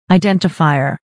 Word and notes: identifier: aɪdɛ́ntəfàɪɚ; アイデンティファイア

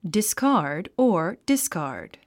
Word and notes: discard: diskάː r d; ディスカード

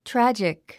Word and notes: tragic: trǽdʒik; トラァジック

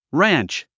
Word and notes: ranch: rǽntʃ; ランチ

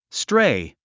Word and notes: stray: stréi; ストレイ

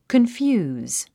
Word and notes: confuse: kənfjúːz; コンフューズ

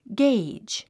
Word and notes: gauge: ɡéidʒ; ゲイジ

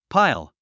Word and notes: pile: páil; パイル

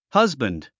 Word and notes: husband: hʌ’zbənd; ハズバンド

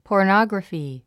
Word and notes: pornography: pɔː r nάɡrəfi; ポゥノグラフィ

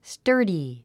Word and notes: sturdy: stə́ːrdi; スターディ

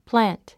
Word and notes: plant: plǽnt; プラァント

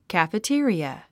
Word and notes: cafeteria: kæ`fətí ə riə; カフェテリア

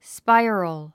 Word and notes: spiral: spáiərəl; スパイロゥ

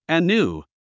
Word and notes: anew: ənjúː; アヌゥ

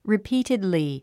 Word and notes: repeatedly: ripíːtidli; リピーテドリィ

